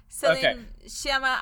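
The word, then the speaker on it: Céline Sciamma: Yeah, Celine Chama. I'm assuming, Chama, based on my limited knowledge of French.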